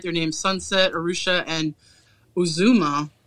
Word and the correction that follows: Uzima: Uzima? Uzima. Uzami? Uzama? – Uzima. Potato, potato. – Let's, you know, let's not get ourselves in trouble with our pronunciation. – I'm going to say Uzima.